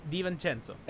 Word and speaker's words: DiVincenzo: The conversation has to be how Spoelstra made Gordon Hayward into Dante DiVincenzo. – Did you say that right? Was that the right pronunciation? – DiVincenzo.